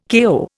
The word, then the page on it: KO: Indian